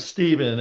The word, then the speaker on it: Stephen: Well, my name's Stephen, S-T-E-P-H-E-N.